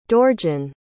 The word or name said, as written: Dorjan